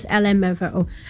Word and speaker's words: LMFAO: How do I pronounce this? Just LMFAO, a massive 2011 hit songs, party rock anthem, has passed away at the age of 37.